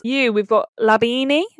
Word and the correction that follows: labneh: Is that how you say it? – Labne.